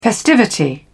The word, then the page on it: festivity: British English